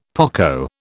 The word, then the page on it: poco: British English